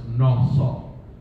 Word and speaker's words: nasa: Another Hebrew word that means forgive is Strong's number 5375, which is nasah, which is spelled phonetically N-A-S-A, but it's phonetically pronounced nasah, which means to lift, to carry, or to take, or to ease a burden.